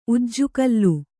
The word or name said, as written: ಉಜ್ಜುಗೋಲು